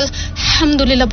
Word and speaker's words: আলহামদুলিল্লাহ: কেন আমরা আরবি ভাষা যখন শিখি, আমি যদি আলহামদুলিল্লাহ না বলি এটা মিনিং চেঞ্জ হয়ে যায়, তার জন্য আমাকে আলহামদুলিল্লাহ বলতে হবে হা দিয়ে, আমি আলহামদুলিল্লাহ বলতে পারবো না।